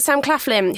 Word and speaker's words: Sam Claflin: We've got Paul Bettany, Toby Jones, again Asa Butterfield, and then we've got Sam Claflin, who well pronounced.